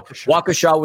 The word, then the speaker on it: Waukesha: Got to give a shout out once again to our buddy Laverty because he was the one that corrected me on the pronunciation. So Waukesha, Wisconsin.